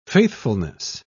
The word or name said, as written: faithfulness